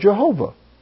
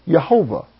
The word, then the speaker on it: Jehovah: But that's how the name comes out if you're going to pronounce it with the vowels from the word Adonai with the consonants from Yahweh. It comes out Jehovah, Yehovah.